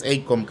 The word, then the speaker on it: AICOM: Ya lo dije mal, lo puse mal, pero es Acom, creo que la... Se escribe A-I-C-O-M.